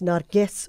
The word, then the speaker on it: Narges: Amelia saying it's Narges with a hard G, so not Narges.